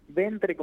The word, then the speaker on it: Ventre: ¿Con E final o agudo o grave? – Ventre, como suena.